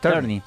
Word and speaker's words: Tierney: Tierney, ¿cómo se pronuncia? – Tierney. – Sí, uh-huh, ok, Tierney.